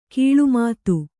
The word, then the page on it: ಗಿಳಿಮಾತು: kannada